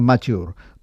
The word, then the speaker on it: Mature: Marcello Maturi, però ell va pensar, Maturi, doncs ho canviaré per la E, i aleshores li pronunciaven Machor.